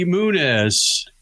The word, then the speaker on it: Muniz: Well, the official way to pronounce it is Muniz. Muniz. Yeah. Muniz.